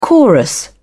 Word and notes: chorus: British English

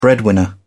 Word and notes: breadwinner: British English

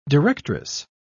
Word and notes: directress: British English

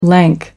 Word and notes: lank: American English

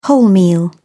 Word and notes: wholemeal: British English

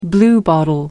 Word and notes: bluebottle: American English

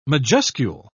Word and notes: majuscule: British English

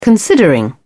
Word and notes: considering: British English

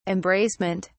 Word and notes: embracement: British English

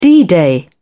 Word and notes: d-day: British English

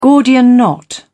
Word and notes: gordian knot: British English

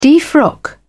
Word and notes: defrock: British English

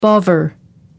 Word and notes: bovver: American English

